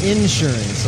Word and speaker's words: insurance: The I have to say insurance also, right? Put the emphasis on the first.